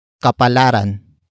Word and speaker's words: kapalaran: And that actor is kapalaran. Kapalaran. Kapalaran. Kapalaran is spelled as K-A-P-A-L-A-R-A-N.